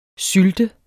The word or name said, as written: sylte